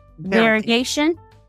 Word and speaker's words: variegation: So I think, to Rachel's point, the trends, the variegated, am I saying that right? – Variegation. – Variegation. Okay.